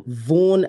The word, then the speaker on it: Vaughn: No, it's I think his name, you pronounce it like Van. It's like a V-A-U-G-H-N, Van. It's not Van Gogh, but it's like Van.